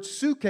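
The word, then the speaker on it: psuche (ψυχή): And then the word for soul is the word psuche. It's spelt psyche.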